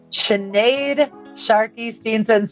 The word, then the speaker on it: Sinead Sharkey-Steenson: Sinead Sharkey Steenson, so great to have you on the podcast today. Did I say that right that time? You got it right.